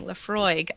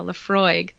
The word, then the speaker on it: Laphroaig: Laphroaig. I know how to say it now. Laphroaig.